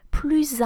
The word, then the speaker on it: plus: So when the comparison refers to an adjective or an adverb, so an adword basically, the S at the end of plus is silent. We'll say plus. For example, more expensive, plus cher. That's an adjective. Taller, that would be more tall, plus grand. An adjective again. Let's take an adverb, more regularly, plus régulièrement. So the S is silent, the final S is silent. However, if your adjective or your adverb starts with a vowel or a vowel sound, you will need to make the liaison, and the liaison will be the Z sound.